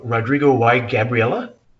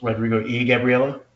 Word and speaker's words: Rodrigo y Gabriela: Well, Rodrigo Y. How do you say it? Rodrigo Y. Gabriela? – Rodrigo E. Gabriela? – Or is it E? – Rodrigo and Gabriela? Yeah. – So it's Rodrigo E. Gabriela? – Yep.